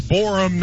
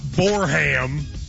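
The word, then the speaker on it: Borehamwood: They said, Matt, your pronunciation is off. It's Borum, not Borham. I don't think I enunciated it like that. I don't think I said Borham like country.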